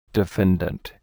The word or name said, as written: defendant